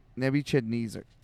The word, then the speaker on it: Nebuchadnezzar: Is it Nebuchadnezzar? Nebuchadnezzar. – Nebuchadnezzar.